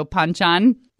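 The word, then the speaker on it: banchan: So it's with a P, even though it's spelled with a B. So Panchan.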